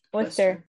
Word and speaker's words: Worcester: I keep saying it wrong. Worcester. – Worcester.